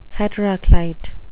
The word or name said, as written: heteroclite